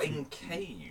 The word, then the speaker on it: encage: who on each side doth you engage to triumph. – In cage. – Yeah, in cage. Sorry, that was my mispronunciation.